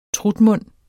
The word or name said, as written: trutmund